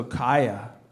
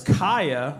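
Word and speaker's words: kaya (חיה): Oh, so kaya. So it means, CH is a K sound, I was just told. So it's kaya, which means to live in Hebrew.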